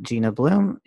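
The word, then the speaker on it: Jeena Bloom: As for me, you can find me at Gina Bloom. That's J-E-E-N. No, spelled my own name wrong. That's J-E-E-N-A-B-L-O-O-M.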